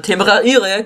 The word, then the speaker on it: Temeraire: nicht wollen eigentlich mehr, bis dann halt der Temeraire kommt. Genauso spricht man das aus und genauso heißt der.